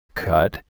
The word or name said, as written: cut